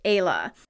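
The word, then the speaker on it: Éala: It's actually spelled E-Fada, A-L-A, and it is pronounced Ayla.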